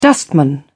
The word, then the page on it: wingman: British English